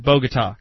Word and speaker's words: Bogota: it's spelled Bogota, and if you weren't living in a dumb state, you'd call that Bogota, because that's what it was named for.